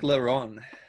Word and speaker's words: La Ronde: I probably totally said that wrong too. But yeah, you did. It's okay though. – It's like La Ronde. – La Ronde. – La Ronde. – Sorry, you have to say like a rumble in your voice.